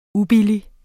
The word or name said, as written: ubillige